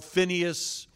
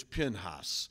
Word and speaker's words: Pinhas: And then today's title and study is on Pinhas, also sometimes called Phinehas, Pinchas. There's different ways to pronounce all of that. Today it's Pinhas. And again in Numbers 25.